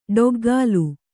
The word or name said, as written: ಡಗಲೆ